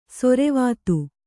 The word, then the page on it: ಸೊರೆವಾತು: sorevātu